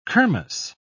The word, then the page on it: kirmess: British English